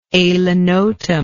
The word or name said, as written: alinotum